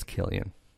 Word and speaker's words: Cillian: But it's a C. It's not a K. – It's Killian.